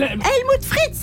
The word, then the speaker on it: Helmut Fritz: Helmut Free. Helmimut. Helmut. Le chanteur Helmut Frit. Helmut. Helmut. Helmut Free Free Free. Bref, frère Foucault. Le chanteur s'appelle Helmut Fritz.